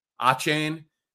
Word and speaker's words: Achane: What, how do you pronounce that dude's name on Miami who had 200 yards and a couple of touchdowns? Achane. Yeah.